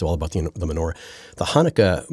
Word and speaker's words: Hanukkah: Hanukkah.